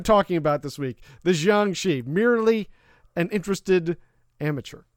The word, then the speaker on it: Jiangshi: Well, then, as you know, it is pronounced Jiangshi. That's what we're going with.